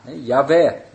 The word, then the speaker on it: Yahweh: Dus daar staat de naam van JHWH, Yahweh waarschijnlijk, zoals je dat moet uitspreken. Yahweh, zegt Jefta, die rechter is, richtte heden tussen de Israëlieten en de Ammonieten.